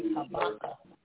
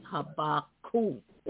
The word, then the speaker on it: Habakkuk: I always pronounce it as Habakkuk. The last part is K-U-K, so it's Habakkuk.